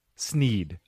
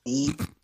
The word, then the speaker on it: Sneed: We've also got Cam Schneed. Sneed. – Hey, guys. – Sneed.